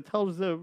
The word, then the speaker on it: Telz: And his place, I believe it's called, they spell it T-E-L-S-I-A-I, but it's really Telz.